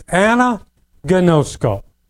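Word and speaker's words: anagnosko (ἀναγινώσκω): but it is anagnosko, the Greek word. Anagnosko.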